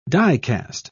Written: dicast

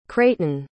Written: crayton

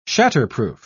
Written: shatterproof

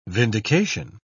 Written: vindication

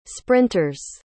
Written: sprinters

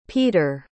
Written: Peder

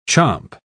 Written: chomp

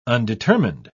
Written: undetermined

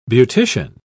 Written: beautician

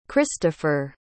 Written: kristoffer

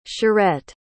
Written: charette

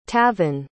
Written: tavin

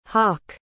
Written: hauk

